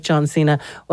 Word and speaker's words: John Cena: So if Little Brother isn't your thing and you do like John Cena, well then we might...